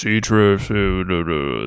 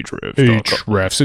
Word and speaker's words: Ahrefs: Yes, Ahrefs. That's how you have to say Ahrefs.